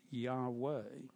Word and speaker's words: Yahweh: That name we pronounce Yahweh.